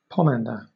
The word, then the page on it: pomander: British English